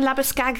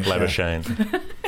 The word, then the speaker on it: Labuschagne: And I know that's not how you pronounce his second name. Remind me again, Labuschagne. – It's Australian, so you have to get Shane in there somewhere. – Bowling Shane, Labuschagne.